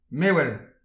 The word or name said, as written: mewel